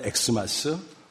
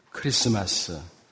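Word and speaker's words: Xmas: 여러분, 이거 어떻게 읽죠? 크리스마스. 크리스마스라고 읽어야 됩니다. 영어 알파벳의 X처럼 생겼지만 사실은 헬라어 크리스토스라는 단어의 첫 글자, 키입니다. 이런 사실을 잘 모르고 사람들이 엑스마스 부르는 것입니다.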